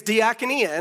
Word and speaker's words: diakonia: And I said it right the first time. Not on Thursday, but I said it right today. Is diakonia.